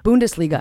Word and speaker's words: Bundesliga: Bundesliga. What's that? – How do you say it? – Bundesliga.